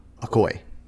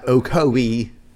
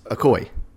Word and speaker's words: Ocoee: Or as the locals say, Ocoee. – Ocoee. I've never heard anyone call it Ocoee before. – That's how you actually say it, is Ocoee. – Ocoee. – Not Ocoee. It's Ocoee.